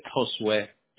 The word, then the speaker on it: Josue: You have it as Jose. It's Josue with the U next to the S. – Oh, let me correct that then. One moment. – Okay. – So... J-O-S-U-E? – Yeah.